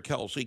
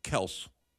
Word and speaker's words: Kelce: but the actual pronunciation is Kels. – Tell his sons that, because they pronounce it wrong, too.